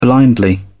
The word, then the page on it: blindly: British English